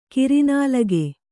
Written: ಕೂರ್ನಾಲಗೆ

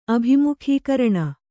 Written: ಅಭಿಮುಖೀಕರಣ

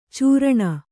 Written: ಚೀರಣೆ

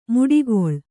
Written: ಮಡುಗೊಳ್ಳು

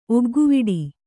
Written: ಒಗ್ಗುವಡೆ

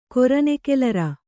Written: ಕೊರನೆಕೆಲರ

